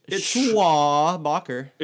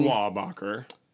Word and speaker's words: Schwabacher: It's Schwabacher. – It's the Charles way it's spelled. Schwabacher, as in Charles Schwab.